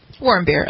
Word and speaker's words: Warmbier: Warmbier, uh-huh.